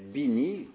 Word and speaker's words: Bini: Eh bien, c'est Bini, donc, alors, on va en Italie, là, hein, c'est un Italien qui s'appelle Bini, comme ça se prononce, B-I-N-I,